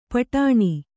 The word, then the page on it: ಪಟಾಣಿ: paṭāṇi